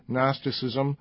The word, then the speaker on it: Gnosticism: It was, for shorthand, called Gnosticism, with a silent G, Gnosticism,